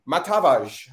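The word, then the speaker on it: Matavž: Slovenia One, a Slovenian football expert, to talk about Tim MATAVZ. Have I pronounced his name correctly? Have I absolutely killed him? – You were actually pretty close, yeah.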